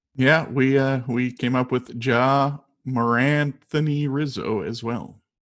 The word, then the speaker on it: Ja: Yeah, so it's pronounced Ja, and it's not two initials. It's not like J.A. Happ or anything. It's actually Ja.